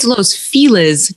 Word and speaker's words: Los Feliz: It's Los Feliz to people who live here because I